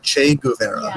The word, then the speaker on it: Che Guevara: Che Guevara. – Yeah, Che Guevara.